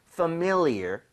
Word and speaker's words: familiar: 그래서 familiar 보다는 familiar. 그래서 familiar. MI 쪽에 강세를 주고 한번 발음해 볼게요.